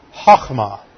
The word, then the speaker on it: chokmah: is the Hebrew word chokmah. C-H-O-K-M-A-H.